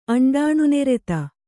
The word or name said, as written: ಅಂಡಾಣುನೆರೆತ